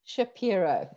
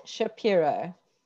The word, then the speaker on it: Shapiro: Shapiro. It's Janine Shapiro.